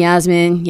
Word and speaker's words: Yasmin: Call me Yasmin. Yasmin, it's not that hard. Rhymes with Kathleen.